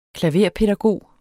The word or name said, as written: klaverpædagog